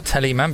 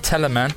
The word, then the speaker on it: Teleman: I also went in there somewhere to see Teleman, right? Which I do believe is the correct pronunciation of that. Not Teleman, but Teleman, named after the composer, someone Teleman.